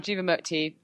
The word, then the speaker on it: Jivamukti: And well done, Jivamukti.